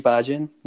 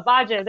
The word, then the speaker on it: Bhajan: Yogi Bhajan. – Yeah, there we go. Bhajan, there we go. He's the one who founded Kundalini Yoga, yes. – I mean, he founded Kundalini Yoga as thought by Yogi Bhajan.